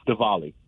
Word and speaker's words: Diwali: And he was like, no, it's Diwali. I'm like, yeah, but it's a W. He's like, no, but that's not how it's pronounced.